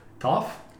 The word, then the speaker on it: Toph: Toffee, but without the E. – Toph. It looks like Toph. It would be Toph.